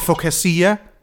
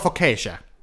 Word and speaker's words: Focaccia: Kan vi gå tilbage til Focasia? Det throwback der, det hedder Focaccia. Okay, men okay.